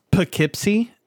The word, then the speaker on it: Poughkeepsie: So it's pronounced Poughkeepsie.